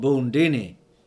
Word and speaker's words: Bundini: I'm called Bundini. Rhymes with Houdini.